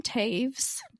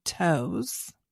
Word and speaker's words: Toews: But it was written by Miriam Taves. – Taves, but it does not look like Taves. – It does not. It's spelled T-O-E-W-S. – Miriam, I would say toes. – Yeah. – But it is not.